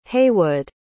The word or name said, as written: heywood